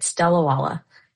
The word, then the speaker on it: Delawala: And you did, you got it right, Delawala, just exactly like it's spelled.